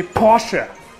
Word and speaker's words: Porsche: In Germany, we say Porsche.